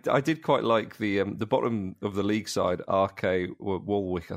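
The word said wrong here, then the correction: Waalwijk: I think it's pronounced. – I think it's Valvik, perhaps. Valvik. – I think it's Valvik. – Is it Valvik, is it?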